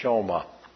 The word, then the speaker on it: Shema: This is called, this Deuteronomy 6, verses 4 and 5, begin a very famous Hebrew prayer, which is called the Shema, it's pronounced Shoma, which begins with the word back in verse 4, where it says, Hear.